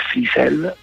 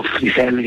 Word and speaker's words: Frisell: la passione per Frisell, o Frisell, che dir si voglia, l'accento lo mettiamo dove capita, perché so, per Marc Ribot, per Tim Berne, insomma, quella per John Zorn, quella scena lì più o meno.